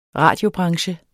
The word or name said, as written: radiobranche